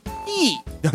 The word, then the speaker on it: 手: はい、 19世紀頃、その前っていう 15 世紀か 18 世紀は手と書いてティーなんですねなんか難しいですね。